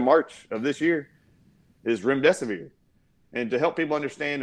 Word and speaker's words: Remdesivir: in order to remember the name Remdesivir, just remember this is how you pronounce it. Run, death is near.